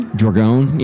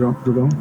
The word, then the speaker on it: Dragone: Did I pronounce his last name correctly? – Dragone. Yeah, you got it. – Dragone.